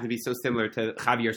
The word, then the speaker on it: Javier: J-A-V-I-E-R, but the way it's pronounced. Javier, which sounds very, very similar to Chivra.